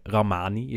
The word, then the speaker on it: Rahmani: Ehm, vervolgens eh, speelde ja, die naam heb ik even opgezocht hoe die je moet uitspreken. Ramani is het gewoon. Rahmani.